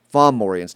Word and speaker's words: Fomorians: The uh, the four, the F O R uh, F O M, and it's Fomorians, not Formorians. Please forgive me for my mispronunciations.